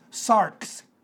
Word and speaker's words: sarx: Now, in the text, the word flesh is the Greek word S-A-R-K-S, or it's the X, which is pronounced as a K-S, pronounced sarx, S-A-R-X, if you please. I always thought it sounded like socks, and I think about dirty socks.